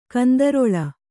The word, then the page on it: ಕಂದರಾಲ: kandarāla